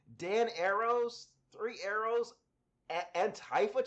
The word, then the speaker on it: Antifa: Apparently, I've been saying Antifa wrong this whole time. Apparently, you're supposed to pronounce it Antifa, because it's not Antifa, it's anti-fascist, so Antifa is the correct pronunciation.